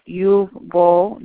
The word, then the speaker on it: Uwe Boll: He goes by the name of Uwe Boll. That is the German pronunciation.